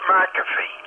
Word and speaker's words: McAfee: I have McAfee. By the way, every time he pronounces it McAfee, that's wrong. It's McAfee. Because it's M-C, not M-A-C-A-F-E-E.